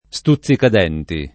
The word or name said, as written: stuzzicadenti